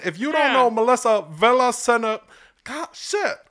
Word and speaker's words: Villaseñor: Okay, so you say Melissa Villa. – Señor.